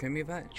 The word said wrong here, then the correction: Triumvirate: Triumvirate, yeah.